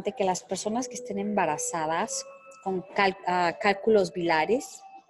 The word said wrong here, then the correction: biliares: bilares si se dice así, o biliares, Ajá, sí, son raro.